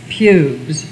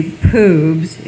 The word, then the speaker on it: pubes: Talk back button, Bill, it's pubes. Unless you want to say poobs, in which case you have to say it that way throughout the whole of the book.